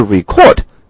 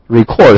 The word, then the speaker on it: record: 同时的这个发音呢，大家注意，不是 record。 Record 是动词，那么如果说是记录的话呢，是 record。